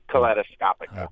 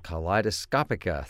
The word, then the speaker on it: Kaleidoscopica: I hope I say it right, Kaleidoscopica. – Kaleidoscopica. – Kaleidoscopica, thank you very much.